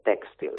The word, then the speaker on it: tèxtil: Textil? – La paraula és correcta, però la pronunciació és sobre la E. És una fàbrica tèxtil.